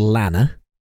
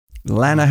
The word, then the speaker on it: Lanner: During this moment, I was in a place called Lanna. – Lanna, how do you spell that? – LANNER.